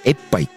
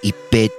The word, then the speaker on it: 一平: 本当だったら、エッパイって読めるんですけど、これで一平って読むんですね。